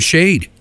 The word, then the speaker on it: Shaed: Keeping the tunes going here with a new track from Shade. And Shade is spelled S-H-A-E-D.